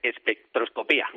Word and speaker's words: espectroscopía: espectroscopia infrarroja. ¿lo he dicho bien? – Pues más o menos sí. Lo que pasa es que te voy a corregir esa última palabra porque es espectroscopía.